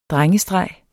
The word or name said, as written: DRENGESTREGERNES